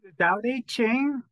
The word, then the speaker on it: Tao Te Ching: So I read most of your book, and which is Practicing the Way of, is it Tao Te Ching, or how do you say that correctly? – Tao Te Ching, Practicing the Way of the Tao Te Ching.